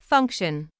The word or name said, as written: function